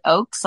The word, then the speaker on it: Ochs: One is that when I first started, my last name is O-C-H-S, and you pronounce it Oaks, like a tree, but yeah.